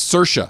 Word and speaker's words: Saoirse: It's spelled so weird. – It's got like I's and O's, and it's Saoirse.